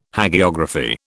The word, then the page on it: Hagiography: British English